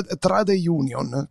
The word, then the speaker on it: trade union: questa tra- trade union, come, la, come, si pronuncia? Ve lo ridica. – Trade union. – Ok, trade union.